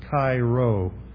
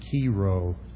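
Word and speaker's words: Chi Rho: You may recognize this central image as a Cairo. Some people pronounce it Kiro.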